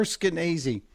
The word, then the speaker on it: Skenazy: She goes, if, if you're saying it like crazy, yes, you are. – Yeah. Lenore Skenazy.